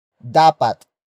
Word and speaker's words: dapat: Dapat ikatuwa ang Pasko.